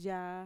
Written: yaa

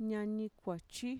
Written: ñanikuachi

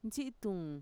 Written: ntxi'ituun

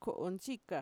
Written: ko'ontxika

